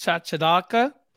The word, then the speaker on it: chachalaca: It's pronounced chachalaca.